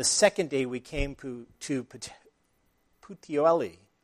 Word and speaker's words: Puteoli: And then they ended up in the Bay of Naples in Puteoli, is the way you pronounce that, Puteoli.